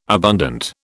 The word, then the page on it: abundant: British English